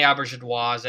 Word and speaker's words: Auberjonois: You know, Rene Auberjonois, did I say that right? I want to make sure I say his name right. – You're getting it.